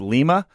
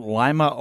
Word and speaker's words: Lima: By the way, we are at St. Rose of I'm so I'm told it's pronounced Lima, as in Lima, Peru. I thought it may have been Lima, Ohio.